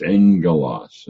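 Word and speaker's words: angelos (ἄγγελος): When you have two gammas together, they're pronounced NGs. And so it's angelos, and that's why it's spelled that way.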